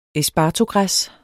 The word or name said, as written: espartogræs